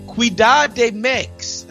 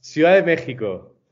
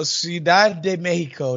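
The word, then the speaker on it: Ciudad de México: I'm joined this time, man, my guy is out in Mexico, Mexico City, Ciudad de Mex. Is that how they say it? – No, not at all. No, Ciudad de Mexico. – Oh, there you go, Ciudad de Mexico.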